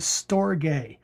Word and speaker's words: storge: And then a love that we may not be as familiar with, but the Greeks expressed it very well, is storge. And that's spelled S-T-O-R-G-E in the Greek.